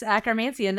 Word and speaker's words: Akkermansia: Is it called, how do you pronounce it? Akkermansia? Is that right? – Yes, Akkermansia. Nice.